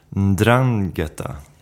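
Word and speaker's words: 'Ndrangheta: Mafia, Camorra och Ndrangheta. Mm. Är det korrekta uttalet? – Ja, ja, ja. Det var bra.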